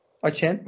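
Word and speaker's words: Achane: Orada bir tek hani şey veren touchdown'ına Devon Aşen oldu. Açen pardon. Geçen, geçen. Geçen. – Açen. İyle okunuyor arkadaşım oldu iyle.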